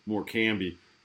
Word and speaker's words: Morecambe: you look at the Morecambe, or as I am obligated to pronounce it, Morecambe match,